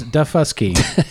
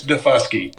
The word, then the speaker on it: Daufuskie: It is Daufuskie. – You got to be careful with that one. – I know. That's what I'm saying. – So, can you verify the pronunciation? – It's Daufuskie.